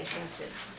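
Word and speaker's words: Chesed (חסד): So you're all going to get a chance to say chesed. – How would you say it? – Chesed. Chesed. It's an H with a dot under it. It's a aspirated H. It's kind of like German CH.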